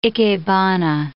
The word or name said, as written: ikebana